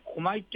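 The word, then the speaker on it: 氷下魚: そうですね、こまいというのは氷の下の魚と書いてこまいと読む魚なんですが、これはちょっと小さめなこまいを干物にして焼いてパッケージしてあるものです。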